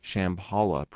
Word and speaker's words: Shambhala: recently published by Shambhala Press. That's S-H-A-M-B-H-A-L-A. Did I pronounce that right? – That's right. – Okay. – Right. – And the author is Larry Dossey, M.D., last name D-O-S-S-E-Y.